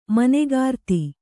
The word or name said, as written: ಮನೆಗಾರ್ತಿ